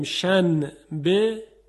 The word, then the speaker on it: شنبه: در فارسی هم داریم شن به، چی تلفظ میکنیم؟ شنبه، یعنی به جای اینکه نون بگیم میم میگیم.